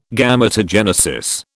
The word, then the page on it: Gametogenesis: British English